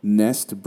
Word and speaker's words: NESD: So the first one is Nest Brain Initiative, but it's spelled NESD, which stands for Neural Engineering System Design.